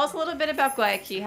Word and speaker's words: Guayaquil: It's like Guayaquil if you're at a door, but yeah.